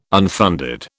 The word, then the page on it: unfunded: British English